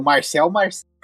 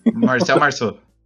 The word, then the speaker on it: Marcel Marceau: É, essas paradas, assim, que era o Marcel Marceau. Eu falei errado o nome dele, mas foda-se, mas é, é o Marcel, é o Marcel Marceau. Marcel Marceau.